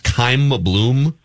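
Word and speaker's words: Chaim Bloom: is it pronounced Keim Bloom? – Oh, yeah.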